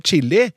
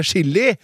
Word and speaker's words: chili: Istedenfor å si chille eller å spise chili, så sier mange nordmenn å chille eller å spise chili med samme SJ-uttalelse som i sjokolade!